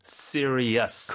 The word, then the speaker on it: serious: 그러면은 series는 S-E-R-I-E-S로 쓰는 그 series인데, 심각한이란 뜻의 단어. – serious. – 그건 또 serious가 되는군요. – 끝에 e-us 발음이죠.